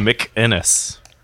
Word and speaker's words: McInnes: Gavin McGinnis. – It's Gavin McInnes.